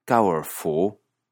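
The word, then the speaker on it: 高尔夫: Golf, el deporte, en chino se dice kaorfu, que es lo más aproximado en sonido a golf. Kaorfu, golf. Kaorfu. Para empezar, queda claro que el chino tiene serios problemas para aproximar el sonido de las palabras extranjeras.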